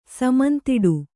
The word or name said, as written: ಸಮಂತಿಡು